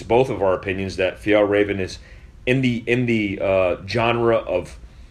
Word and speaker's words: Fjällräven: Oh, I was thinking about the Fall Raven stuff. – Yeah. – And how's it pronounced? – Fjällräven.